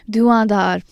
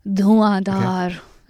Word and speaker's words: dhuandar: Duandar, let's pronounce it. So, you know, you see these Mughal words. I love pronouncing them because I can speak Urdu. So they are, you've got to say it with a umph. Duandar.